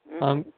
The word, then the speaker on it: Ankh: You know that symbol for the Ankh, A-N-K-H, or whatever it is, that thing, that Egyptian Ankh? Ankh, or whatever, I think it's called, pronounced Ankh.